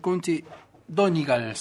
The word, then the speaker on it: Donegal: Hay una banda de County Donegal, se pronuncia así, se escribe Donegal, que es al norte y al este de Irlanda, que se llama Clannad.